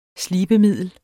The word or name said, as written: SLIBEMIDLET